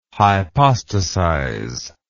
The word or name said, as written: hypostasize